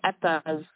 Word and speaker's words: Edda: But I wanted to say, I understand that you are an actress nominee for the, am I pronouncing this right, the Edda or Ida Awards in Iceland? Is that like our Emmy Awards over here? – Yes, exactly. It is the Eddas.